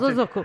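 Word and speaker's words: Bōsōzoku: I believe is how you pronounce Bozozoku.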